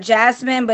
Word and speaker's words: Jasmin: It actually is Jasmine, but it's spelled different. It's without the E. So it's Jasmin.